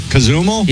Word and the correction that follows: Cozumel: The correct pronunciation is Cozumel.